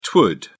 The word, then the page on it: twould: British English